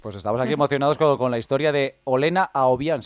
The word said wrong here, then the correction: Akopian: ¿se pronuncia así? – Olena Akopian, sí, una de Akopian.